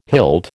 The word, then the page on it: hilt: American English